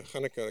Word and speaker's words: Hanukkah: So Hanukkah, I don't know how to pronounce it, somebody will help me out. There, Hanukkah, okay, great, I know.